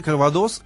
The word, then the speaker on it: кальвадос: Ну да, ну, во Франции, во французских словах на последнее гласное делается ударение. Кальвадос, коньяк.